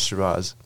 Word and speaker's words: Shiraz: When the COVID ends, visit Shiraz. Is that how you how you pr- pronounce it? – Yes. – Yes. Shiraz. – Um, Vahab, thank you very much for coming on to the show.